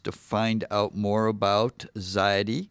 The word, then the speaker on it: Xyity: And just to repeat for everybody, Ziety is spelled X-Y-I-T-Y.